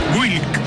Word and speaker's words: Wilk: sonando como una sola sílaba con una I corta entre la W y la K. – ¿Te das cuenta? – Wilk.